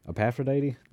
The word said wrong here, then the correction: Aphrodite: Is that what I said? – Yeah. – Oh, it is Aphrodite. I pronounced it wrong. – Oh. – My apologies. So it's Aphrodite. That is correct.